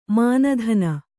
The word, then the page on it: ಮಾನಧನ: māna dhana